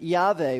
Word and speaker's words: Yahweh: But literally, it's just four letters, and we would pronounce it Yahweh. We anglicize it, and we say Jehovah.